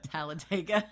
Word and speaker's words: Talladega: Talladega.